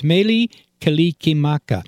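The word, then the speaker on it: Mele Kalikimaka: Okay, Meli, it's two words, Meli Kalikimaka.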